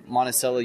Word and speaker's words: Monticello: I'll be driving from Dallas to Salt Lake, and I'm going to pull over in Monticello, Utah. Yes, it's pronounced Monticello, not Monticello. I don't know if they named it after Thomas Jefferson's house, but that is named Monticello.